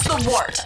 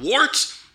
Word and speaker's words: Wort: This is The Wort on Gubs Radio. – It is The Wort. Not wart, not fort, not short. The Wort.